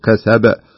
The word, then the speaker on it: كسب: شوف لو نطقناها بدون ما ننطق القلقلة تجد حرف ضعيف أو لا ينطق حتى لا يسمع أحيانا. كسب، شوف لاحظ كسب كأنك ما تنطق الباء، ما كان تنطق الباء، لا بد أن تقول كسب بهذا الشكل.